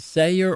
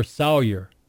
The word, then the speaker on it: Sayer: Is it Sayer or Sawyer? Leo Sawyer or Sayer? – Leo Sayer. – Sayer. Okay, because I've always said to see say Saw. So it's Sawyer. – Sawyer is a spice.